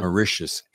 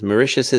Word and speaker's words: Mauritius: Mauritius. It's you like should. Delicious. That's what I need to remember. – It is. It's like delicious. It's Mauritius is delicious. Yeah. – Mauritius is delicious.